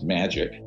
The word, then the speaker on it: MAJIC: So that's where I get into the Majority Intelligence Committee, MAJIC. It's pronounced magic, ironically, or not ironically.